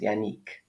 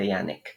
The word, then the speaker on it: Yannick: So my name is Yannick, and I guess if we're just going to go into linguistics, originally the name is, or traditionally the name is pronounced Yannick, but I guess how things end up when it comes to English speaking places, it's turned to Yannick.